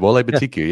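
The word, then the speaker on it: Oluwole Betiku: Most notably, I'm going to butcher his name. Oluwale Batiku. – No, that's pretty good. Wole Batiku, yeah.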